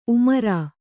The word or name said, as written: ಉಮ್ರಾ